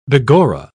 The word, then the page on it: begorra: British English